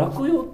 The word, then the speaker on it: 落葉: これ落葉って読む説と落ち葉って読む説と 2 つあるんですけど、上田凜。